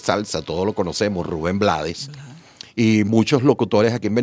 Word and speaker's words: Blades: Y él respondió, si mal no recuerdo, palabras más, palabras menos, yo soy de Panamá, hablamos español y nosotros debemos pronunciar lo que leemos. Ahí dice Blades, por lo tanto yo entiendo que es Blades.